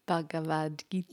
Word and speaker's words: Bhagavad Gita: Bhagavad Gita.